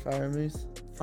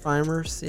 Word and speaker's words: Freiermuth: Packers, Aaron Rodgers, Damian Harris, Michael Thomas, Devontae Smith, and Pat Freem. How do you pronounce his last name? – Pat Freiermuth. – Freiermuth, yeah.